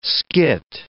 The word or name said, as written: skit